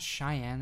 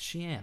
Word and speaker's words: Xi'an: Xi'an is a hard one. I never remember if I'm doing it right or not. But I think it's Xi'an or... It's not Cheyenne. It's Xi'an.